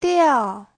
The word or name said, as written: diao4